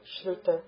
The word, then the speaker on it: shruta: Should say shru, shruta. – It's a short. Yeah, should be a short U there.